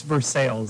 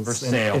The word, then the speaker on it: Versailles: Versailles, they won. – It's Versailles. – Versailles.